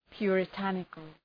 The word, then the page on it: puritanical: ,pjʋrı’tænıkəl